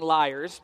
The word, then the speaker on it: lyre: Following them were musicians playing lyres, and I always struggle. Lyre seems weird, but that's actually the correct pronunciation of the word.